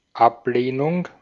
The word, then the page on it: Ablehnung: [ˈʔapleːnʊŋ]